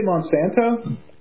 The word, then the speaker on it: Monsanto: Monsatan? – Yes. – Did you say Monsanto? – Yeah, I said it wrong.